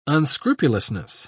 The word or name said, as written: unscrupulousness